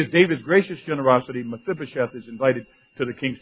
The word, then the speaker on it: Mephibosheth: Mephibosheth. Mephibosheth. And don't think I didn't practice that. Kind of refreshed my pronunciation a couple of times before I came here this morning. Mephibosheth.